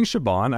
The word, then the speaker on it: Siobhan: Am I saying it right or wrong? Is it Siobhan or Siobhan? – Am I saying Siobhan? I think it's Siobhan. – Hold on. Well, I think it's Bond.